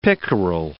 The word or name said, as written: pickerel